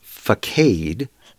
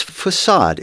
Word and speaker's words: facade: The word is facade, and it looks like it's pronounced facade, but it's facade.